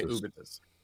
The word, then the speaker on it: Ubitus: because Ubitus, or however you say it, U-B-I-T-U-S. How would you say that? Ubitus? Ubitus. We're going to say Ubitus.